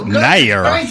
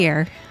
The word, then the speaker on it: Nair: Sorry, Nair. That's how you say it. – Nair.